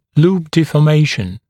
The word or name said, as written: loop deformation